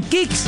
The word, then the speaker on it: Kixx: Así como los campeones se preparan para ganar, protege tu moto con Kicks, K-I-X-X. Aceites sintéticos y semisintéticos para un máximo rendimiento. Síguenos ya mismo en Instagram como @aceite_kicks. Facilito, @aceite_kicks. Ese kick se escribe muy fácil, K-I-X-X. K-I-X-X, kicks.